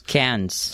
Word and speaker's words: Cairns: Cans.